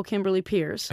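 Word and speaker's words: Kimberly Peirce: Kimberly and then P-E-I-R-C-E. – P-E-I-R-C-E. – And you can get me on Instagram but somebody stole Kimberly Pierce.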